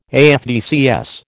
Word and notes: AFDCS: American English

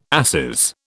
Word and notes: asses: British English

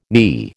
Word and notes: NI: American English

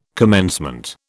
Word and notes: commencement: British English

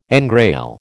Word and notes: engrail: American English

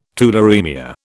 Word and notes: tularaemia: British English

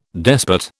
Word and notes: despot: British English

